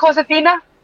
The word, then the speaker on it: Josefina: You said it right, Josefina.